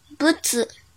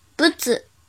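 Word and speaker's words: ぶつ: 和小一一起学日语第一百零五课今日汉字“ 物”，音读的时候读作“ 不子”，“ 不子”，“ 不子”。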